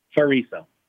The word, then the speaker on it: Ferriso: So is it Ferriso or Friso? – Ferriso.